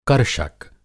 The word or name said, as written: कर्षक